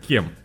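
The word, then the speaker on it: Cem: Э, ну, то есть, короче, читается либо кем, либо чем. Вот я не знаю. Кем чем, я, я не знаю. А, кем чем? – Нет, вот кем.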